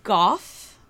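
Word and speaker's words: Gough: Michael Goff, I think is how you pronounce it.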